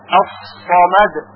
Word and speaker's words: الصمد: الله الصمد، الله الصمد، کلمه صمد با صاد باید تلفظ بشه نه با سین. الصمد،